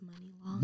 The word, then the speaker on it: Money Long: It's pronounced Money Long. – Money Long, sorry. What did I say? I said Mooney Long, my bad.